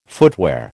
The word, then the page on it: footwear: American English